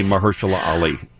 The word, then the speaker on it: Mahershala Ali: I'll see you tomorrow morning on the WGN-TV Morning News with Mahershala Ali and Viggo Mortensen. Guys, I'm excited about talking with them, but I'm just so proud that I can finally, after 2 years, pronounce the name Mahershala Ali.